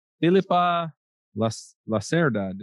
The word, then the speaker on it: Filipa Lacerda: This week, we're talking to Filipa Lacerda.